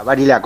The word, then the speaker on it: Barilá: Así estuvimos con Roque Varila. ¿Varila o Varila, Roque? – Ah, Barilá, con acento en la.